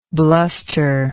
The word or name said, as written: blusterously